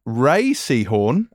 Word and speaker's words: Rhea Seehorn: Uh, Rhea Seehorn, as I found out, is well actually done.